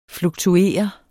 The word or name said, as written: fluktuere